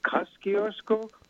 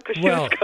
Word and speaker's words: Kosciuszko: Is it Kosciuszko, Kosciuszko? – Well, what is it? – Well, I know it's Kosciuszko if you're Polish, but it's Kosciuszko because that's the way it's pronounced in Brooklyn, the same as the train station that's close to our station.